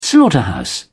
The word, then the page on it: slaughterhouse: British English